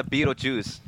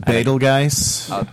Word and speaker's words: Betelgeuse: That's me with the Beetlejuice. – Beetlejuice. – I believe it's pronounced Betelgeuse.